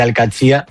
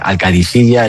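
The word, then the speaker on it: Al-Qadsiah: Bueno, se suele decir Al-Qadsi, pero también hay gente que dice Al-Qadisiyya. Es que es difícil, ¿eh? Ah. No es fácil, pero Al-Qadsi es lo más fácil.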